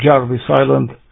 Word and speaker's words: Jarvis Island: Voi începe și astăzi cu câteva cuvinte despre ce se mai întâmplă pe Jarvis Island, sau Jarvis Island, cum se mai pronunță peste ocean, acolo unde expediția de X November 5 Juliet și-a plasat echipamentele și a început activitatea radio.